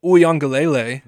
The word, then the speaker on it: Uiagalelei: Clemson quarterback DJ Uiagalelei.